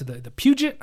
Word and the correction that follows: Peugeot: I call it. The Peugeot. – The T is silent. Yeah.